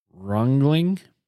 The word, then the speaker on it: Ringling: It's Rungling. It's German French German. Uh, they were the son of a German French German immigrant. Uh, they later changed their name to Ringling because it's hard to say.